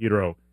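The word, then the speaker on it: Jiro: And it's about the greatest, this guy is considered J-I-I-R, I believe it's pronounced Euro.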